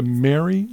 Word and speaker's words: MERI: Is it the Mary Center? Is that how you pronounce it? – Yes, M-E-R-I.